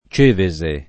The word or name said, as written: Cevese